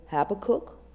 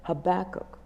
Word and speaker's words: Habakkuk: Before I go on, let me say a word about the pronunciation of Habakkuk. You may hear Habakkuk, you may hear Habakkuk. They're both acceptable pronunciations, and my puny brain has not settled on the one I prefer.